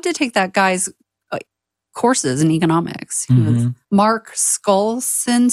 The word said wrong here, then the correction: Skousen: Skousen. Pronounced it right. – What? – It's Skousen, S-K-O-U-S-E-N.